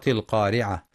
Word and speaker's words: القارعة: في سورة القارعة: بسم الله الرحمن الرحيم، القارعة، ما القارعة، وما أدراك ما القارعة. ينتبه القارئ لتفخيم القاف في أعلى مراتبها لأنها مفتوحة وبعدها ألف،